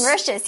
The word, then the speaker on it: Mauritius: Mauritius, yes.